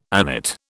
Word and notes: agnate: British English

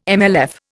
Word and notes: MLF: Indian

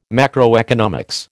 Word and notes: macroeconomics: American English

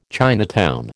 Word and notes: Chinatown: American English

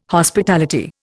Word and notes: Hospitality: Indian

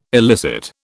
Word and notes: elicit: British English

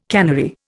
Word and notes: cannery: Indian

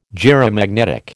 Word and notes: gyromagnetic: American English

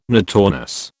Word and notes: notornis: British English

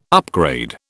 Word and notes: upgrade: British English

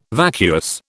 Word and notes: vacuous: British English

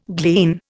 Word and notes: glean: Indian

